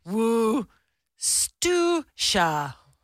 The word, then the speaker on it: Worcestershire: Worcester. – Worcestershire. – How is Worcestershire actually pronounced? It's pronounced Worcestershire.